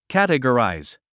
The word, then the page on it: categorize: kǽtəɡəràiz; キャテゴラァイズ